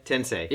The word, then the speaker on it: Tensei: Shin Megami Tensei. – Tensei.